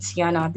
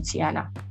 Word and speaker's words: Tiana: You're seeing the T and the Anna, so it's going to be easier to pronounce that way. So that's the reason I changed to Tiana.